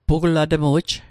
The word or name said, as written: pugulatmu'j